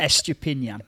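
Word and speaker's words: Estupiñán: I'd have put my house on Estepunian scoring against us on that one-on-one. – Hold on, can we... Hold on, what was that pronunciation again? – Estupinian. – Estupinian.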